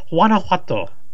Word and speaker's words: Guanajuato: He says, from Beaverton, Oregon, / Guanajuato, Mexico. Did I say that right? – Oh, my God. Okay. – What? – Is it spelled G-U-A-N... A, is it? – Yes. – It's Guanajuato.